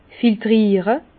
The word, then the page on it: filtriere: Bas-Rhin; Herrlisheim